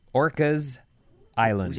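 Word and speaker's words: Orcas Island: It's Orca's Island, not Orca, Vinny. – How do you say it? – Orca's Island. – Just Orca's with an S.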